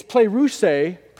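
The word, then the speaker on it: pleroussathe (πληροῦσθε): Now guys, when he says be filled with the Spirit, that word in the Greek actually is pleroussathe, is actually how you pronounce it.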